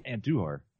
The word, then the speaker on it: Andujar: you gotta say the do. – Yeah, that's his name. – Duhar. – Just say his name. – Andujar.